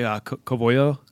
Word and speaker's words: Covello: So the name Covello, it has English and Spanish origin. So co means collaboration. Velo is pronounced velo, if you can say it the Spanish way.